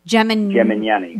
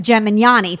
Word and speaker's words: Gemignani: I always say his last name wrong. – Gemignani. – Gemignani, thank you.